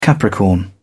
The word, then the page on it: capricorn: British English